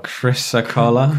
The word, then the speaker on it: chrysocolla: Oh, chrysocolla.